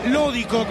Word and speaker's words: Lódico: Este es para Lódico, con acento en la primera O.